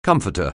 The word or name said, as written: comforter